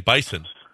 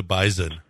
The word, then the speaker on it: Bison: We went up there and we were saying Bison. And they were like, no, we're the Bison. – What? – They pronounce it with a Z up there. – With a Z, yeah, that's correct.